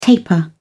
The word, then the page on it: taper off: British English